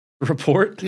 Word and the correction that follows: rapport: Is that wrong word? I say it wrong? Rapport.